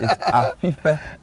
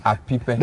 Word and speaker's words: Apipe: So that is where Atiteti near Apipe. – Uh-huh. You are surprised I'm saying it right. – No, no, no, you're not saying it right. It's Apipe. Uh-huh. It's like you're whistling. It's not Apipe.